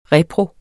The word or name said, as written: repro